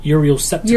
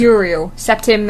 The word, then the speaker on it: Uriel Septim: Uriel Septim. – Uriel Septim, the 7th, for about 5 minutes.